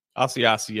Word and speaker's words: Asiasi: Is that how you say it? – Asiasi, yes.